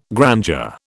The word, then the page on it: grandeur: British English